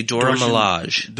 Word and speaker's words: Dora Milaje: The Dora Milaje.